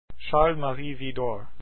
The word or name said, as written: Widor